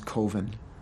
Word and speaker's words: Coven: The name of the film is Coven. – It's pronounced Coven, man. What else could it be pronounced? – Coven, that's the proper pronunciation. – No, Coven sounds like oven, man, and that's just, it doesn't work.